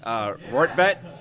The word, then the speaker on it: Rortvedt: And picked up Ben Rortvedt. How I pronounce names properly? – Yes. – Rortvedt.